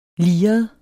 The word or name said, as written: liret